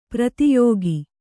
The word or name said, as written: ಪ್ರತ್ಯೇಕ